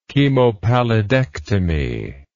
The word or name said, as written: chemopallidectomy